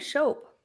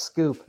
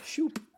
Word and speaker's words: Schoep: Is that how you say it? I've been saying Shope. – Yeah, no, it's Scoop. – Shoop.